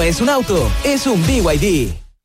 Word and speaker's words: BYD: ¿Los VIP? – ¿VIP? ¿No es VIP? – Nada que ver, se escribe BYD, pero se dice BYD, la marca número uno de electromovilidad en Chile y el mundo.